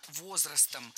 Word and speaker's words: возрастом: Возрастам. Опять неверно. Правильно, возрастом. Перед нами существительное с неподвижным ударением на основе. То есть ударение всегда падает на «о» в первом слоге.